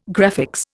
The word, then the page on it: graphics: Indian